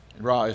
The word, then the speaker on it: Rahe: According to the Holmes Raah survey or scale, Raah is spelled R-A-H-E, that's how I pronounce it.